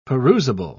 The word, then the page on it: perusable: British English